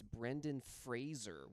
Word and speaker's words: Brendan Fraser: Oh, yeah, we've learned that it's Brendan Fraser. We've all been saying it wrong for decades.